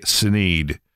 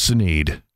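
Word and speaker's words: Sinéad: I hear him go, ha, ha, ha, ha, here's Sinead. And so we told him, hey, that's not how she pronounces that. It's pronounced Sinead. Well, that's not correct. I'm sorry. That's her name. That's how she says it.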